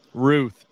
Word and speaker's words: Routh: His last name is Ruth, not Roth, Alex.